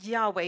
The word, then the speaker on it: Yahweh: God's name, we pronounce it Yahweh, even though there's no vowels in there.